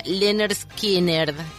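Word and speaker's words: Lynyrd Skynyrd: Bueno, y si te pones a leer un poco la historia del nombre, lo que te cuenta es cómo se pronuncia, que sería Leonard Skinner, en este chiste que les estábamos contando de reemplazar todas las vocales por la Y.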